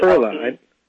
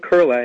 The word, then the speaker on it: Koehle: It's pronounced Kurla. You have to be a good German to know that. – Kurla? – My husband is from Germany, and in German they put a umlaut, a little dot dot over the O, and that makes the O sound like a U-R. So you get Kurla. – So Orlene Kurla. – And it's spelled K-O-E-H-L-E.